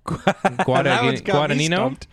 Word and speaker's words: Guadagnino: Alusa Guadingmo? Nope. Guadingino? How would you say that? – Guadagnino? – Guadagnino? – Guadagnino? – Yeah, Guadagnino.